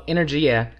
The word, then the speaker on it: Energia: Yeah, well, Energia is, and that is how you pronounce it, is Italian. It's the Italian word for energy.